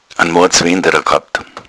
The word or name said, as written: wenderer